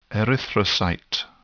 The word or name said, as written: erythrocyte